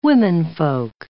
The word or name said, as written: womenfolk